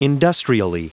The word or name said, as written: industrially